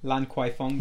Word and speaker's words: Lan Kwai Fong: Is it Lan Kwai Fong? – Lan Kwai Fong.